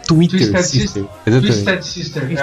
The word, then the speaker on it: Twisted Sister: O vocalista do Twisted Sister, tô falando certo? – Twisted Sister.